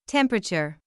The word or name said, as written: temperature